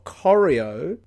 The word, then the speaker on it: Corio: And he's he represents the seat of Corio, which is Geelong. – Corio. – Oh, is that how you pronounce it? – Yeah, Corio in Geelong.